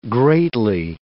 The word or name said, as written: greatly